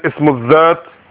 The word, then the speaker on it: ism al-Dhat: So ism al-Dhat, again, that's how you pronounce it, ism al-Dhat, which means the concrete noun.